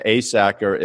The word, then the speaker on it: Asacker: So if you go into Google and you put Tom and then Asacker is like it sounds, A, Sack, and then E-R, A-S-A-C-K-E-R.